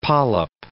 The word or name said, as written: polyp